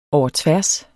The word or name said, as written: overtværs